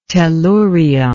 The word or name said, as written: tellurion